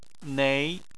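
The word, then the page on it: nei: tone 2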